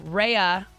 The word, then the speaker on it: Raya: Raya is how it's pronounced, I found out.